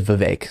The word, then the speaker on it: Vivek: I, for a while, mispronounced it as Vivek. And a lot of people have said Vivek and all these different things. It is Vivek.